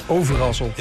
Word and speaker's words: Overasselt: Overasselt. Ja, de rest van Nederland kan het niet goed uitspreken. – Overasselt. – Ja, overasselt.